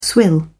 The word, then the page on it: swill: British English